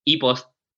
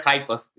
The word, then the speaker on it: Hi-Post: They use like a Spanish word, and they call e-post. – E-post. Yeah, I like both, but I like High Post. It's more English. – Yeah, I prefer High Post. That's the real name, the real pronunciation.